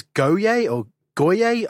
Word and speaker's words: Goyer: In 2001, also a guy called David, I think it's Goyer, I've never been able to pronounce his name. – Goyer. – There we go, that's him.